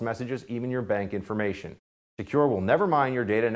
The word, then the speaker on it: Sekur: That's why you need a new cybersecurity application tool called Secure. It's spelled S-E-K-U-R. Secure is using proprietary encryption and offering secure instant messaging and email.